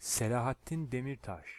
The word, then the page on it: Selahattin Demirtas: Turkish